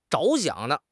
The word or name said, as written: 着想